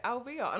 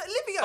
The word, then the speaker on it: Olivia: Yeah, but then when she read the letter, she was like, so what is it? Alvia? And I was like, no, it's Olivia.